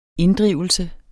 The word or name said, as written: inddrivelse